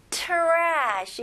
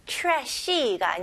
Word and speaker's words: trash: 여기서 tr로 시작하지만 여기서는 chr 이렇게 발음하시면 훨씬 더 발음하기 쉬울 거예요. 그래서 trash 이렇게 하는 것보다는 ch 발음으로 시작해서 trash. trash. Very good. 이름절로 하셔야 됩니다. trashy가 아니고요. trash. Okay.